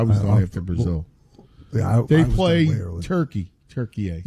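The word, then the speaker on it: Türkiye: Turkey A just decided to change the pronunciation of their country about 2 years ago. That threw me for a loop. I had to cover this more aggressively for the Euros. And so I made that mistake a couple times of pronouncing it incorrectly.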